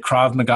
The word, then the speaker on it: Krav Maga: Like she's taking like, is it Krav Maga? I don't ever know how to say it. – Yes, that's how you say it.